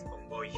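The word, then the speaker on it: Kongoy: Y sí, el libro es Congoy. Congoy. – Congoy. Muy bien.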